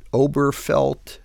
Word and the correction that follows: Obergefell: the gay marriage case. – Obergefell. – Obergefell. Yes. I never get it right.